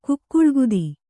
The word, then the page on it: ಕುಕ್ಕುಳ್ಕುದಿ: kannada; kukkuḷkudi